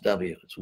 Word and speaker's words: vit: It's spelled V-I-T, vit, but if you study ancient Sanskrit grammar, it becomes clear that the V was actually pronounced W, it's wit.